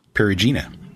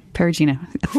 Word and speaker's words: Perugina: What Italian city is home to celebrated chocolate maker Perugina? Perugina? – Perugina. – Perugina.